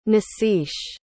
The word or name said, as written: nasiche